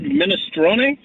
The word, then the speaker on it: minestrone: Any Italian word that has a vowel on the end, that vowel gets used. So if the E is actually part of the word minestrone, then that E gets used.